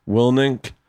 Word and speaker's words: Willink: I always say his last name wrong, Willink, Willink. But I get inspired by all these fucking guys.